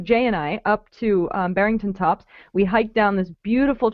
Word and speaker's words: Barrington Tops: Well, Johny took us up to Barrington Tops. Did I say that correctly, Johny? – Yeah, that's correct. Yeah.